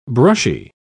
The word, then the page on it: brushy: British English